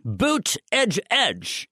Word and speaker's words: Buttigieg: Pete Buttigieg, Buttigieg is how he says you ought to pronounce his name.